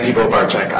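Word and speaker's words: Piwowarczyk: Dan Pivovarczyk on behalf of the people. My last name is spelled P as in Paul, I-W-O-W-A-R, C as in Charlie, Z as in Zebra, Y-K.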